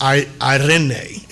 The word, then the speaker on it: eirene: This particular word, peace, it's spelled E-I-R-E-N-E in the Greek, and it's pronounced Irene. It's how it's pronounced.